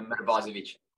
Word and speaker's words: Mrvosevic: Yes, Mrvosevic.